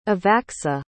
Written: evacska

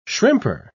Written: shrimper

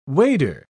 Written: wader

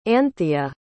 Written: antheia

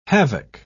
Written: havoc

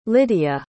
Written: lidia

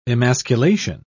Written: emasculation

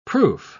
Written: proof

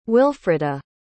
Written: wilfreda

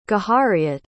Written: Gahariet

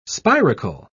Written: spiracle

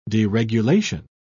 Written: deregulation

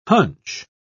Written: hunch

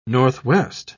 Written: northwest

